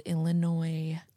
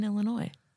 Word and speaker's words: Illinois: And it takes place in our home state of Illinois. I believe it's pronounced Illinois. Um, I believe you're wrong, ma'am. I believe that's wrong. FYI, to everyone listening, the S is in fact silent in Illinois.